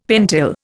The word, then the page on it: pentyl: Indian